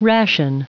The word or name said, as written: ration